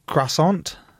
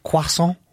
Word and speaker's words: croissant: No, why are you pronouncing the R? – Go on then. – You just said croissant. – How do you say it? – Croissant. – Okay, that's good. Croissant.